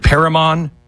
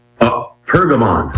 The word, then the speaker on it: Pergamon: It's called the Altar of, is it Perigmon? Perigmon? How do you say it? – Pergamon. – Pergamon. Pergamon. The Altar of Pergamon.